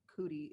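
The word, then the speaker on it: Coodie: I think it was amazing for Cootie, is how it's pronounced, like Cootie, which is the documentary.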